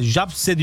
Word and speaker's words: Zabrze: ön eleme turu ilk maçında Polonya'nın Gornik takımını Gornik Zabrze, Japse diye okunuyor.